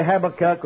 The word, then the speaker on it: Habakkuk: Habakkuk, some say Habakkuk, but it don't matter to me how you pronounce it.